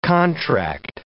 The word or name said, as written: contract